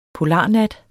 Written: polarnat

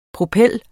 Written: PROPELLER

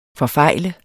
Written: forfejle

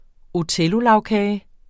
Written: othellolagkage

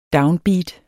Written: downbeat

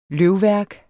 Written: løvværk

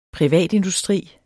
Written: privatindustri

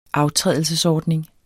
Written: aftrædelsesordning

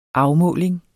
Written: afmåling

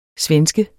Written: svenske

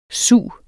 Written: sug

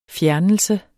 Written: fjernelse

